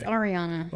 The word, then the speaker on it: Ariana: You met, and you always tell me I say the name wrong, so how do you say it? – Ariana Grande? – Ariana Grande. – What do you say? – Ariana Grande. – It's Ariana.